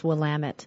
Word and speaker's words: Willamette: But it is, I believe it's an Indian word, or it's based on an Indian word, and it's Willamette. So the emphasis is kind of there in the middle.